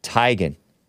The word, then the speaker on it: Teigen: I think it's really supposed to be pronounced Tigan.